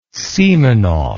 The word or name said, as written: simonov